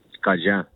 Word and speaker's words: Cajun: Parce que de ce que je comprends, t'es un des rares jeunes actuellement qui parle encore couramment le Cajun. Je le prononce bien, c'est ça? Cajun ou Cajun? – Cajun. – Cajun, ok, voilà, je me disais Cajun, super. – Même comme Acadien, les Acadiens.